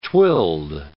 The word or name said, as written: twilled